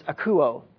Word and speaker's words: akouo: So the first word, the Greek word for hear, is akouo. Sounds like the word acoustic, okay, to hear.